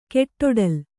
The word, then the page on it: ಕೆಟ್ಟೊಡಲ್: keṭṭoḍal